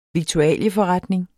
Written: viktualieforretning

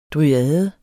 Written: dryade

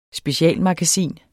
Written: specialmagasin

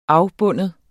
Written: afbinde

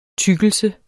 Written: tykkelse

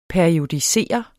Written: periodisere

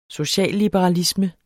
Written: socialliberalisme

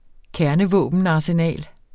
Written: kernevåbenarsenal

